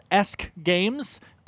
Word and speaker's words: Esk Games: I believe it is pronounced Esk Games. Esk is an old English word for ash, evidently.